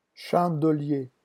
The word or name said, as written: chandelier